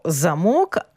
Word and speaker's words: замок: І це стільки, коли ми говоримо про замок, а тепер перейдемо до замку. Замок із наголосом на перший склад – це укріплене житло феодала доби середньовіччя з оборонними, господарськими, культовими та іншими будівлями, звичайно, оточене високим кам'яним муром з кількома вежами.